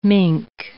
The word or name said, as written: mink